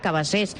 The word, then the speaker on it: Cabassers: el que passa que es pronuncia com en català Cabassers, per exemple, eh?